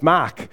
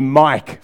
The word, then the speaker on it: Mark: I said, my name's Mark. And he called me Mike for 10 days. And so after 10 days, he goes, oh, Mark.